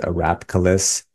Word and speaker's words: Arapkiles: Last name is Arapkiles, ARAPKILES.